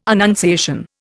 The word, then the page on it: annunciation: Indian